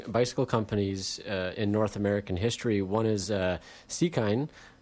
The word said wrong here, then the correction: Sekine: or Seekin, or however you want to pronounce it. – Sekine, according to the typical Romanji pronunciation.